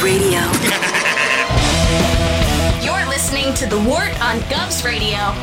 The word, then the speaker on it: Wort: It is the Wort, not Wort, not Fort, not Short. The Wort.